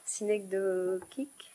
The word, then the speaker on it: synecdochique: Synecdochique, chic, je ne sais pas. – C'est chic. – C'est chic, bon.